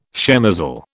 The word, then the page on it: shemozzle: British English